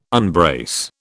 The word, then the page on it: unbrace: British English